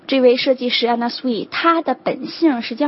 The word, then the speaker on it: Anna Sui: 下一个牌子呢，其实是来自于咱们华裔的一个设计师哈，中文应该是叫安娜苏对吧，这个牌子它的正确发音应该是 Anna Sui。 OK， Anna Sui 而不是 Anna Sui， Anna Sui 是正确的发音。